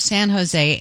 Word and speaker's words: San Jose: Mayor of San Jose. Rhymes with L.A. Mayor of San Jose,